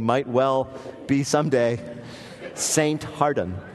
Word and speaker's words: Hardon: I imagine he pronounced it Father John Hardon, H-A-R-D-O-N.